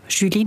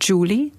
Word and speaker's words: Julie: Es ist ja Julie, nicht Julie.